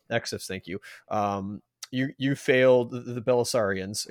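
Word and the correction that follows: Bilusaludo: Again, I completely... – Belisaludo. – Belisaludos, thank you.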